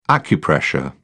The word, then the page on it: acupressure: British English